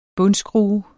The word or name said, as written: bundskrue